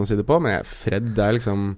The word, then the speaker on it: Fred: Ikke Fredge, men Fred.